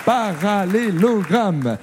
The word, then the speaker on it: parallélogramme: On prononce bien toutes les syllabes. Parallélogramme.